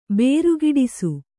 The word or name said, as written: ಬೇರುಗಿಡಿಸು